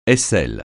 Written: aisselle

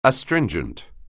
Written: astringent

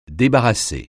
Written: débarrasser

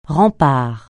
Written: rempart

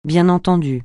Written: bien entendu

